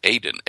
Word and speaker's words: Aden: And so I went over and I took my Google Earth, and I found the volcano called Aden, which is the way you pronounce the Garden of Eden in Hebrew and Arabic. It's Aden, A-D-E-N.